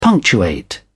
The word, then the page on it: punctuate: British English